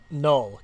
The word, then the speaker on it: Knull: Like all the lore and stuff they've now set up with how do you pronounce Krull? – Null. K-N-U-L-L. Yeah.